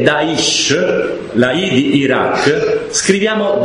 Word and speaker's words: Daesh: Daesh, cioè Daulah al-Islamiyya fi Iraq wal-Sham, Daesh. Ma per pronunciare Daesh hanno messo la E, Daesh, quindi un inglesismo.